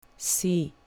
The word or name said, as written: c